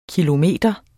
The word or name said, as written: kilometer